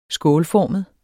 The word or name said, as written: skålformet